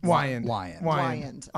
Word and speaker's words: Wiand: This is Cole Wynoski, you said your last name? Wynoski? – I didn't pay attention to the pronunciation. – Wyand. – Okay. Like, why end this call? – Why?